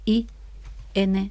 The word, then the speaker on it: Invanep: Vamos a decir que, por si alguien quiere entrar en la web para mirar, Invanep se escribe INVANEP. En Artes Gráficas 23, pero la página web es Invanep. – Con P, de Pamplona, de papá.